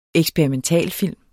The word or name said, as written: eksperimentalfilm